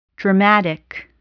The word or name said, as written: dramatic